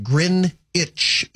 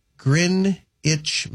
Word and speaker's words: Greenwich: Text messenger says this, FYI, Greenwich Mean Time is pronounced Grin-itch, as in green, as in Grin-itch meantime.